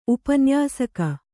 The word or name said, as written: ಉಪನ್ಯಾಸಕಿ